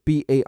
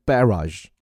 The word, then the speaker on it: barrage: 这里这个词 barrage 念法呢有两种，英国人重音在前 barrage，美音呢会念成 barrage，但不管怎么念，听这种有点奇怪的发音就知道它来自于法语。